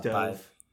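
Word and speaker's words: Dov: It's funny, like, then I don't really know how to pronounce her ex-boyfriend professor's name, like Dove or whatever. – It is Dove.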